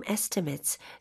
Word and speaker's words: estimate: 그 길을 따라 Down the road, we offer free in-home estimates, so there are no surprises down the road. – 네. estimate이라고 하는 것은 견적을 명사로 나타낸 건데 그때 발음할 때 mate이 아니었죠.